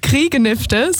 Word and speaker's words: Griegnifte: Griegnifte, so spricht man es bei uns aus.